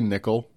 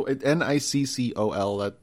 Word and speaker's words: Niccol: Nicole? How would you pronounce? – Andrew, I would say Niccol. – Niccol. All right, that's a good name. – Like the coin, just the Niccol.